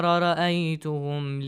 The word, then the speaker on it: رأيتهم: Pay attention to the pronunciation of ra. Sometimes you pronounce it lightly. والشمس والقمر رأيتهم. That's not Arabic, fluent Arabic. You got it. It should be fluent Arabic.